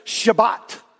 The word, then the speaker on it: Shabbat: The Jews pronounce it Shabbat.